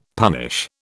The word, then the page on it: punish: British English